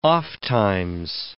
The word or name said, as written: ofttimes